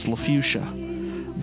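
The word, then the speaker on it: LaFaucia: My last name is LA, capital FAUCIA, and it's pronounced LaFuchsia.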